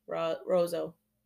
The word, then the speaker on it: Roseau: Called Ross, current day Rosio, Minnesota? – Rozo. – Is that how it's said? – R-O-S-E-A-U. Yeah, Rozo, Minnesota.